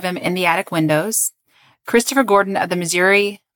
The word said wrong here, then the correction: Missouri: Oh, Missouri. – It's like now I'm mispronouncing words I actually know.